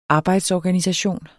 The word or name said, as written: arbejdsorganisation